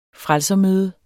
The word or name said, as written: frelser-